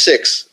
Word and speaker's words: Sikhs: And from the time I'm with the Sikhs and the time I'm with, by the way, pronounced Sikhs. I've learned that. I've learned all these little, it's not the Sikhs, it's the Sikhs.